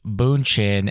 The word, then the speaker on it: Bündchen: The dress actually was apparently a look that Giselle Bündchen, yes, Bündchen, and I had to look up how to pronounce that, wore in Esquire magazine circa 2004.